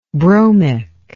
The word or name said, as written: bromic